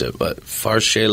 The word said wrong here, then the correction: Cherchez: la femme? – Cherchez. – Cherchez.